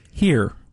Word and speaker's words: Herre: H-E-R-R-E. How you pronounce that? – Here.